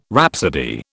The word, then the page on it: rhapsody: British English